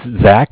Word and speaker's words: Zach: Yes, it's Zach, spelled Z-A-C-H. – Okay. – From the Bank of Cardiff. Cardiff is spelled C-A-R-D-I-F-F, as in Frank.